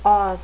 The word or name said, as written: os